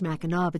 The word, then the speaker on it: Mackinac: The Mackinac Center, it's pronounced Mackinac, but spelled Mackinac, N-A-C,